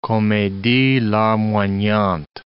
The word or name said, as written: comedie larmoyante